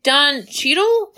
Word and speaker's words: Cheadle: and it's represented by Don Cheadle. I hope I'm saying his name right. – Do you mean Don Cheadle? Like, oh, Cheadle. Oh my god, Cheadle, yes.